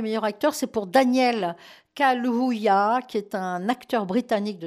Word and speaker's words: Kaluuya: Kaluuya, c'est pas facile à prononcer parce qu'il y a deux U qui se suivent. Kaluuya, acteur britannique, voilà.